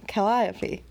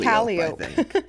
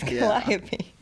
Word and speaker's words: Calliope: Calliope. – Yeah, We kept saying Calliope. But it's Calliope. – Yeah. It's a weird spelling, I feel like. – Mm-hmm. It's very phonetic. Calliope.